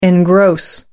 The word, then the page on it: engross: American English